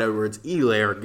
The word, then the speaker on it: Edwards-Helaire: So I think this is a prime Clyde Edwards-Helaire game. The H is silent.